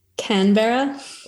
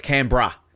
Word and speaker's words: Canberra: Canberra. – Ooh, close. Canberra more sort of more right. cam.